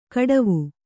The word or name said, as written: ಖಟ್ವ